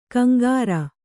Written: ಕಿಂಕರ

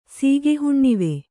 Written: ಸೀಗೆಹುಣ್ಣಿವೆ

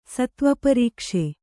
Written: ಸತ್ವಪರೀಕ್ಷೆ

